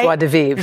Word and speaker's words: joie de vivre: Did I say it right? – Joie de vivre. – Joie de vivre. – Yes.